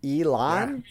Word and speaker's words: Elon: Yeah, it's Elon. – It's spelled what you think it was? – Ellen. E-L-E-L-O-N. – It's not Ellen. Like Ellen DeGeneres? – Yeah. I thought it was pronounced Ellen.